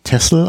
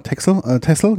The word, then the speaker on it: Texel: Aber es wird eigentlich Texel ausgesprochen. – Genau, Texel, genau.